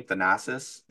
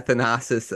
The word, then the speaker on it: Thanasis: Thanasis. – Yeah, Thanasis.